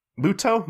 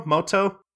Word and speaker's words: Muto: um, but anyway, so really the basic premise of Yu-Gi-Oh kind of starts off with uh, with Yugi Muto Moto Muto. Yeah. they mispronounce his name in the Four Kids stuff or whatever, so I'm used to calling him Moto.